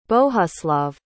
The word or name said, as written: bohuslav